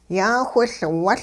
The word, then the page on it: yaawhiLwuL: hupa